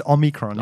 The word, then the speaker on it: Omicron: I think we're saying it right. It's Omicron.